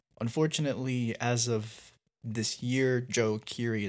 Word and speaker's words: Djo: It's called it's spelled DJO, but it's just pronounced Joe.